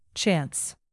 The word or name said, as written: chance